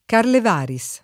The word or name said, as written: Carlevaris